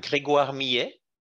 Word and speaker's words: Grégoire Millet: Uh, I'm Grégoire Millet.